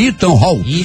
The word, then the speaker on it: Ethan Hawke: Ethan Hawke. Será que é assim? Ethan Hawke. Ethan Hawke. Isso mesmo.